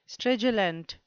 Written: stridulant

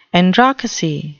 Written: androcracy

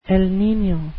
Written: el nino

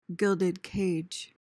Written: gilded cage

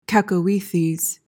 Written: cacoethes

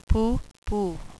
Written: pooh-pooh

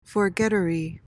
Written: forgettery

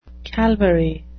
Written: calvary

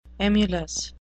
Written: emulous

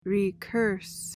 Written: recurse

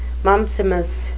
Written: mumpsimus

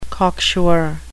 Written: cocksure

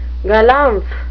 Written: galumph

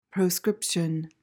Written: proscription

